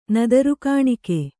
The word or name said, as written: ನದರುಗಾಣಿಕೆ